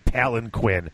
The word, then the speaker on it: palanquin: That's how you say it, palanquin. Sure, if you want to mutilate the English language.